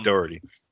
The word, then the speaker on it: Dougherty: Well, I know there is a little thing, Michael Dougherty, or however you say his name, the creator of this. – Yeah, Dougherty.